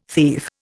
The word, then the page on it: thief: Indian